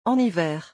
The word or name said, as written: en hiver